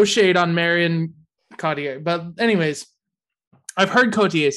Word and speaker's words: Cotillard: No, it's French. – I know. – You can't say Cotillard. That's given too much credit to the letters that they use.